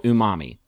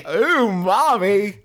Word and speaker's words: umami: And it's this hyper powerful zest taste is going to be called umami. Oh no, wait, I said it wrong. Umami.